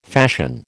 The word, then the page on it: fashion: American English